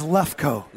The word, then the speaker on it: Lefkoe: My last name is Lefkoe.